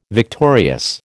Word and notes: victorious: American English

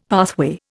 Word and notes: pathway: Indian

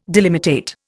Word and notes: delimitate: Indian

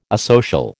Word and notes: asocial: American English

